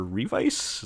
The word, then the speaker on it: Revice: Kamen Rider Revice. Is that how you say it? It's been a long time. – Yep.